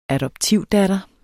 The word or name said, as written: adoptivdatter